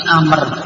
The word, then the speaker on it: Amr: Jadi kalau tulisan Umar kok ada wawunya, itu bukan Umar, tapi Amr. Amr ibn al-As. Tapi kalau tidak ada wawunya, itu bacanya apa? Umar. Jadi sudah kita ngerti.